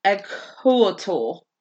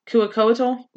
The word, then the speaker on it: Quetzalcoatl: No, their C's were pronounced as K's, actually. So it'd be Quetzalcoatl? – Quetzalcoatl? – Yeah.